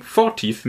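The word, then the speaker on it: Fortit: Na gut dann, dann, freuen wir uns einfach, dass wir wissen, dass man es Fortieth ausspricht. Oder andersrum, Fortieth mit äh, Betonung auf der ersten Silbe.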